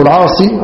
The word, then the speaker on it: العاصي: والعاصي هو أبو عمرو رضي الله عنه، وينطق وهكذا ويكتب بإثبات الياء، هذا هو الفصيح، اللغة الفصيحة كما بيّن النووي أن تثبت الياء فيقال العاصي وليس العاص بحذف الياء، وبحذف الياء صحيح لغة أيضا،